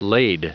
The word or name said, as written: laid